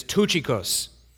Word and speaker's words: Tychicus: I thought it was Tychicus. It's Tychicus. Tychicus, a beloved brother, faithful minister, fellow servant in the Lord, will tell you all the news about me.